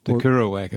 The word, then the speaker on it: Kerouac: Went up to Auckland and did a thing called the Kerouac Effect. That's a very small community that does poetry with music, which is a small community. – Yeah. Did you say, what was that? The Kerouac Effect? – Kerouac Effect, yes.